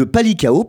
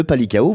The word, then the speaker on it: Palikao: issu de la bataille de Palikao, pardon, la bataille de Palikao, voilà, je le prononce bien,